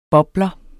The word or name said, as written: bobler